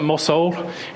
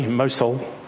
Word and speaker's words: Mosul: Nineveh was situated on the Tigris River, across from Mosul in Iraq. I've said it wrong again already, Mosul, which you've already heard of.